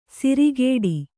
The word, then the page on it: ಸಿರಿಗೇಡಿ: sirigēḍi